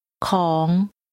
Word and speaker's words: ของ: ดิฉันอยากไปซื้อของค่ะของของ The word for thing or something is pronounced with a rising tone.